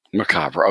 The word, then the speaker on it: Macabre: So it's called the Macabre, I guess if you want to say French, the Macabre Motel. – However you want to say fine by me. It's a freaky place. Let's just put it that way. – Well, it's your book, so how do you want to pronounce it? – Macabre. – Macabre. Okay, so we're Americanizing.